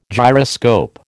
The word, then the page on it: gyroscope: American English